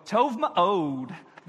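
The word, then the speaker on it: tov ma'od (טוב מאוד): And if you want to say things are very good, you go tov ma'od. That's how you say it in Hebrew.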